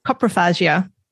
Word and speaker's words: coprophagia: Um, if I'm saying it correctly, coprophagia. – That's right.